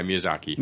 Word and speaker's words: Miyazaki: Animation director, Hayao Miyazaki? – Yes. – Is that how it's pronounced? – Yeah, Miyazaki.